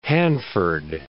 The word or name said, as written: Hanford